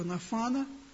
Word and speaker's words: Нафан: И это было ясно сказано самому Давиду через пророка Нафана. Сегодня благословляли Натанчика, но это еврейское имя Нафан. Оно произносится, может произноситься по-разному, но вот пророк Нафан изрек обетование, да, Давиду, что на его троне сядет его сын.